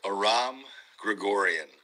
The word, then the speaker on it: Aram Grigorian: It is pronounced Aram Grigorian.